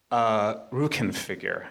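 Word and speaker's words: Rückenfigur: Rukin figure. I never know if I'm pronouncing that right. – Perfect.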